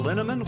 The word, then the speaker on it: Linneman: Val Linneman, which rhymes with cinnamon,